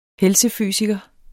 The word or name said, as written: helsefysiker